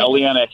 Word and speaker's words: Oleinik: Anyway, really, the premiere, the fight that's on the card, Lewis and Oleinik, am I pronouncing that properly? – Yeah, Oleinik.